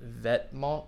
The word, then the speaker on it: Vetements: How do you pronounce that? The other one. – Vetements.